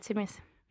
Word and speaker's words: Temiz: Ja, Temis.